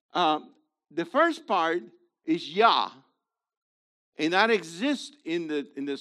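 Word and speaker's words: Yah (יה): So no question, the first syllable is Yah.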